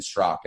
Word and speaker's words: Straka: And if they want to check me out on social media, it's at Brandon Strock. And like you said, my last name is spelled S-T-R-A-K-A.